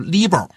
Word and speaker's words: LIBOR: 我有一年在外面上课，跟同学们讲这个伦敦银行间同业拆借读 LIBOR。然后呢，下了课有个学生跑过来跟我说，说老师你这个发音不对，应该读 LIBOR。他是海归。我想我这人知错能改啊。我到了下堂课我就读 LIBOR 了。然后后来呢，读了 LIBOR 之后，下了课又一个学生跑过来说他也是海归，应该读 LIBOR。